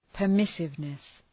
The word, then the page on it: permissiveness: pər’mısıvnıs